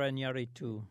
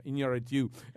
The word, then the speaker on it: Iñárritu: Alejandro Iñárritu. – Yeah, Iñárritu.